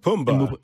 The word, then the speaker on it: Pumbaa: How do you say it? Pumbaa? – Pumbaa. Pumbaa's yeah, right.